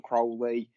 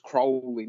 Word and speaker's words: Crowley: Aleister Crowley. I don't think I've pronounced Boleskine House, but it's like I've been told it's Crowley, not Crowley. It's Crowley. just like to tell people,